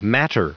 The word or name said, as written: matter